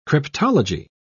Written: cryptology

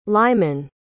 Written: lyman

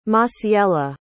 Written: Masiela

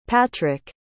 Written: patric

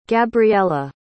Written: gabriella

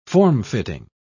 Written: formfitting

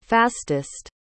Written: fastest

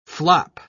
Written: flop

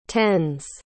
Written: tens

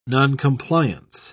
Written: noncompliance

